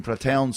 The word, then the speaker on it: Pratteln: 18th Pratteln, Switzerland.